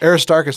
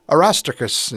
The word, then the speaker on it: Aristarchus: You could go to Greece and be like Aristarchus, and they're like, who? That's like how so many of us. No, it's Arostarchus.